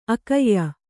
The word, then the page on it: ಆಖ್ಯೆ: Kannada